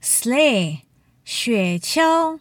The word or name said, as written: sleigh